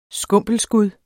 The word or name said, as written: skumpelskud